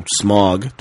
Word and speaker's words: Smaug: It is not Smaug. It is Smaug.